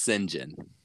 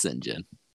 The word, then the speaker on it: St. John: It's pronounced St. John. – St. John? Okay. Uh-huh. – That's how it's pronounced in the UK and Australia, both of which is where he's from, but not really.